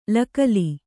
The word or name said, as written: ಲಕ್ಕಲಿ